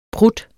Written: prutten

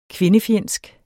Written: kvindefjendsk

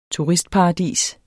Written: TURISTPARADIS

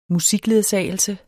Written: musikledsagelse

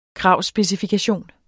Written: kravspecifikation

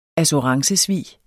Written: assurancesvig

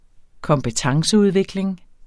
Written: kompetenceudvikling

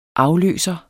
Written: afløser

